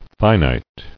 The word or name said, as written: Finiteness